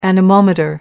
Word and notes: anemometer: American English

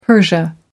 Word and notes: persia: American English

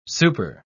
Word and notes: super-: British English

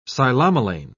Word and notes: psilomelane: British English

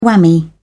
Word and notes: whammy: British English

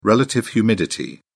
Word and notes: relative humidity: British English

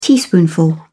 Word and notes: teaspoonful: British English

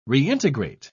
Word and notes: reintegrate: British English

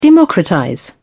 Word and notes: democratize: British English

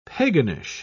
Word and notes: paganish: British English

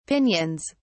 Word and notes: pinions: British English

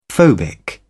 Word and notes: phobic: British English

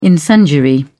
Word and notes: incendiary: British English

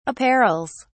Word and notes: apparels: British English

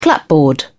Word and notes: clapboard: British English

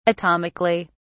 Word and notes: atomically: British English

